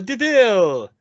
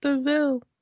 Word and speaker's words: DeVille: No, I'm Cruella DeVille. We did not need... – It looks like devil, but it's pronounced DeVille. – Yes, I don't think we needed to know how she got her last name.